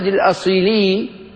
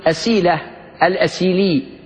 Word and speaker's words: الأصيلي: أبو محمد الأصيلي نسبة إلى مدينة أصيلة بالمغرب، وينطق بالسين أيضا أسيلة الأسيلي، وينطق بالزاي كذلك كما تقدم معنا في بعض فصول كتاب الشفاء.